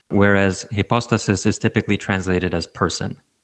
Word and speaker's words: hypostasis: But in the singular, it would be hypostasis, yeah.